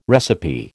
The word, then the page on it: recipe: American English